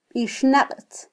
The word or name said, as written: bixnaqtz